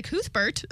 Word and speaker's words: Cuthbert: 2007, which is still during Summer Spiro, he met Alicia Cuthbert, I think is how you say it, who's an actor, right? – Yeah. Cuthbert? Sorry. No, that's correct.